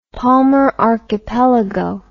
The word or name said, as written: palmer archipelago